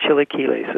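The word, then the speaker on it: chilaquiles: Yeah, a little more harder key on that. Chili Quiles is how you pronounce it.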